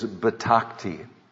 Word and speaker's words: betakti: In fact, it's interesting, in the Hebrew language, the word for trust is betakti. We might spell it this way, B-E-T-A-K-T-I, betakti.